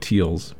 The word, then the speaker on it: Teilz: And we began with something new from LA's Tiles, which is a person's last name, and it's spelled T-E-I-L-Z, so I'm pronouncing it like it's German, where if it's E-I, it's the I sound, and the I-E is the ear sound, so she may actually be called Teals.